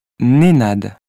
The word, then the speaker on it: Nenad: Non, Nénad, avec un N. – N, comme Noémie ? – Oui, N-E-N-A-D. – 2. – Je m'appelle Chine. – Pardon, épelé s'il vous plaît. – S-C-H-I-N.